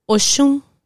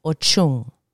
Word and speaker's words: Oshun: Oshun. Oshun. And in Spanish, Ochun. Again, remember the SH turns into a CH.